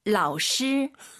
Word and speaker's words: 老师: 老师. – 이거는요? – 老师. – 뭐가 달라요? 그렇죠. – 发音不一样吧? – 발음이 달라요. 선생님은 老师 끌어줘야 되고 이건 사성이죠. 老师 이런 게 어렵죠.